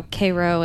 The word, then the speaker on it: Cairo: And they make a thing about how Cairo and Illinois is pronounced Cairo.